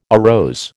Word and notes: arose: American English

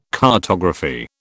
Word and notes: cartography: British English

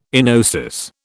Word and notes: enosis: British English

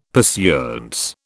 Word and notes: pursuance: British English

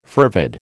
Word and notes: fervid: American English

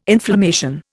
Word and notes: inflammation: Indian

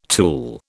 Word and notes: tool: British English

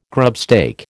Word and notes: grubstake: American English

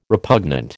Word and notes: repugnant: American English

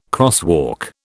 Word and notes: crosswalk: British English

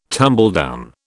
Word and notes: tumbledown: British English

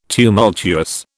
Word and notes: tumultuous: British English

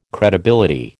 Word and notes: credibility: American English

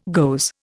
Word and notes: goes: Indian

